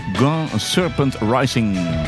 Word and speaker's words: Grand Serpent Rising: Grand Serpent Rising. Grand Serpent Rising, zo zou je het moeten uitspreken.